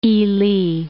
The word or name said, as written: Ili